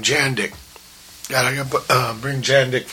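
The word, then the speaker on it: Jandek: Hey, Jandik rhymes with Jack Chick.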